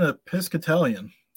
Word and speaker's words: Episcopalian: Well, as an Episcopalian, I said that right.